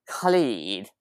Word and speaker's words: Khalid: And I thought I kept thinking it was Ka, but it's like Khalid. And yeah, like kind of like a ch sound.